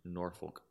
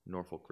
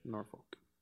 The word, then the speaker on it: Norfolk: So you'll, ha- it says, unfortunately, you'll have to head down to Nor- Norfolk. – Sorry, I'm saying that wrong. Norfolk, Virginia. – Norfolk.